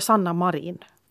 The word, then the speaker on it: Sanna Marin: Ja, jag har fått lära mig att vi ska uttala det som hon själv säger det. Mm. Och då blir det Sanna Marin.